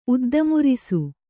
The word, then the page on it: ಉದ್ದಮುರಿಸು: Kannada